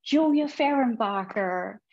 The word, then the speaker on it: Julia Fehrenbacher: I'm your host, and we're here today with guest speaker, poet Julia Fehrenbacher.